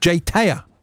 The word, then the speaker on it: JTAYH: It's written as, I'll say this because I'll stop doing it, but J-T-A-Y-H, but pronounced Jay Taya.